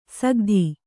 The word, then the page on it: ಸಕತಿ: Kannada